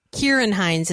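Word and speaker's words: Ciarán Hinds: So Kieran Hines is how you pronounce the actor's name who plays, the King Beyond the Wall, Mance Rayder.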